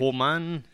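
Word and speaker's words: Kilchoman: We're going to hear later on in the podcast from a guy called James Wills, who is an expert of Kilmaine. Did I say that right? – Kilmaine, yes.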